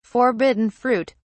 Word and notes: forbidden fruit: British English

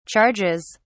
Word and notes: charges: British English

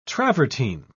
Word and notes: travertine: British English

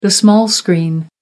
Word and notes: small screen: American English

